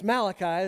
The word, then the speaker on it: Malachi: It's Malachi. It's the hard C-H.